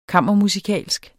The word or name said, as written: kammermusikalsk